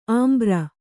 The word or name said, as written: ಅಂಬರಿ